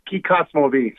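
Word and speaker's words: Kykotsmovi: I wasn't raised around this area, but there's a way of saying it in the Hopi way. But yeah, it's pronounced Kikotsmovi.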